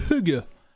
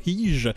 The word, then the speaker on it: hygge: Donc à ceux qui sont de nature stressés, on vous suggère le hygge. – Le hygge. – Donc mon danois est parfait, vous l'entendez. Ça s'écrit H-Y-G-G-E, donc il faut vraiment savoir que ça se prononce pas hyge.